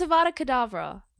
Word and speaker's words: Avada Kedavra: It's Avada Kedavra.